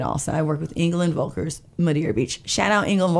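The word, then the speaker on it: Völkers: L-K-E-R-S. And that is to pronounce F instead of V. So it's Engel and Volkers.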